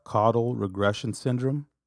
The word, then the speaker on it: caudal regression syndrome: You know, you kind of touched on it, but you have caudal regression syndrome, if I'm saying that correctly. – And yeah, that's correct.